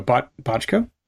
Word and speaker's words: Bochco: How do you pronounce his last name? B-O-C-H-C-O. – Oh, Bochco? – Bochco, yeah. Oh, so yeah, okay.